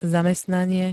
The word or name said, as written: zamestnanie